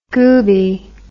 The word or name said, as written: gooby